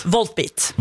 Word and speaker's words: Volbeat: og jeg fikk oppleve Volbeat, det riktig uttalt? – Voltbeat.